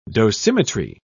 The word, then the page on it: dosimetry: British English